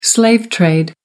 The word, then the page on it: slave trade: American English